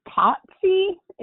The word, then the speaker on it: Tatsi: It's Totsy. It's spelled T-A-T-S-I.